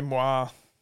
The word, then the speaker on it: Moir: How do you say it again? – I'd say Moir. – Yeah. Yeah. Moir Stakes.